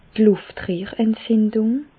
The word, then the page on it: d' Lùftrehrentzindùng: Alsatian; Bas-Rhin; Reichshoffen